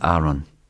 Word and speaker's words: Aaron: Die Zwillinge bekamen den Namen Jesse Garron und Elvis Aaron. Die Zweitnamen sollten sich reimen. Aaron, mit einem langen A und der Betonung auf der ersten Silbe, kam von Vernons Freund Aaron Kennedy.